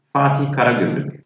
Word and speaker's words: Fatih Karagümrük: Fatih Karagümrük.